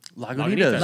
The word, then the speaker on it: Lagunitas: It's, what, Lagunitas? – Lagunitas.